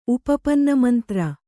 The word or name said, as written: ಉಪಪನ್ನಮಂತ್ರ